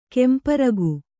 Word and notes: ಕೆಂಪರಗು: kemparagu

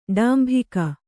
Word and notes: ಡಾಂಬಿಕ: ḍāmbika

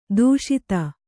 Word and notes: ಧಾಷತ್ತು: kannada

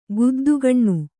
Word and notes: ಗುದ್ದುಗಣ್ಣು: guddu kaṇṇu

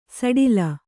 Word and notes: ಸಡಿಲ: saḍila

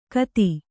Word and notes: ಖತ: Kannada

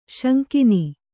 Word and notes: ಸೆಂಗೆನಿ: sengeni

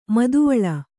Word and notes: ಮಾದವಾಳ: mādavāḷa

